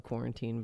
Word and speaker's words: Quarantine: It's actually probably just called Quarantine, but it rhymes with wine.